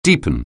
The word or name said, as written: deepen